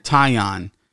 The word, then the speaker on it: Taillon: And Taiwan, I can't say his name. What is it? Taiwan? – Hold on. – Taiwan? – Taillon.